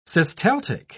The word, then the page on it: systaltic: British English